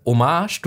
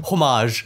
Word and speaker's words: Hommage: Top 3 Hommage, du hast schön das H lautlos ausgesprochen.